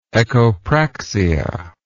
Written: echopraxia

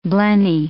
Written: blenny

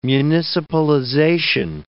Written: municipalization

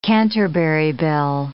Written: Canterbury bell

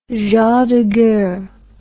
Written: gens de guerre

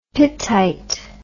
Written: pittite

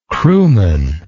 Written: crewmanship

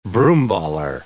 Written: broomballer

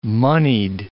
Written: monied

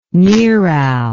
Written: neral

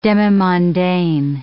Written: demimondaine

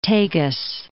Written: Tagus

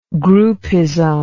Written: groupism

